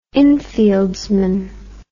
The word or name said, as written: infieldsman